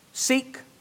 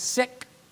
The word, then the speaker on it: Sikh: The man who is sick, Sikh is how I pronounce it, but they pronounce it sick, from India, texted me yesterday.